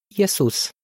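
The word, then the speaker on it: Iesus (Ἰησοῦς): Creștinii din secolul I care vorbeau ebraica trebuie să-i fi pronunțat numele Iehoșua, iar cei care vorbeau greaca, Iesus.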